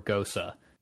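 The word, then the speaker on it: Gose: Is it pronounced goes or gosa? – I looked goes. – Online and it said gosa, but...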